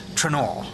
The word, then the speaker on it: Trainer: Just for the record, it is pronounced Trenor.